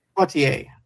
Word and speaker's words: Poitier: Poitier.